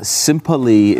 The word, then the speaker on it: simply: Simply is a two-syllable word. Okay, it's not three.